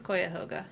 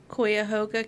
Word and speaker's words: Cuyahoga: How do you say this? – I think it's Cuyahoga. – Cuyahoga County,